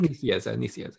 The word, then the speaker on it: Nicieza: No, I think it was Nicieza. – Okay. – How do you say his name? – Like, Nicieza, yeah.